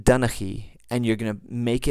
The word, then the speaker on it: Donnchaidh: So with Clan Duncan or Donnchaidh, you have this letter that pops up in the middle that doesn't necessarily need to be spelled into there the way Gaelic does it, but it will be pronounced Donnchaidh.